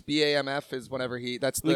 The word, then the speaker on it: BAMF: You can pronounce it however you want, but that's how it's spelled. And I like pronouncing it boof. – That's definitely not how.